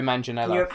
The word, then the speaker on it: Manganiello: How do you pronounce his surname? – Joe Manganiello.